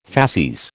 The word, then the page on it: facies: American English